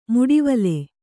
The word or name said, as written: ಮಿಡಿವಿಲ್